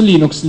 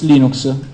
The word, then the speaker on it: Linux: Voi avete sentito tutti quanti il file audio di Linux che dice this is Linux Torvald, I would like to pronounce Linux Linux. Sono Linux Torvald, io pronuncio Linux Linux.